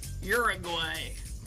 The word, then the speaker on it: Uruguay: Uruguay. Had to say it like an American. – Yeah. Can you say it as a non-American? – Well, it doesn't start with a your sound. – Oh. – Uruguay? – Uruguay?